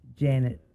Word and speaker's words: Janet: Janet. J-A-N-E-T. – And that's Janet, not Jeanette.